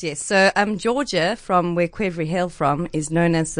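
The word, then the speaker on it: qvevri: But in English, we spell it with a Q and it's pronounced quevry.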